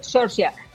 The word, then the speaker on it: Saoirse: le preguntaron que cómo se pronunciaba su nombre y ella dijo que Saoirse, mejor que ella no lo va a saber nadie.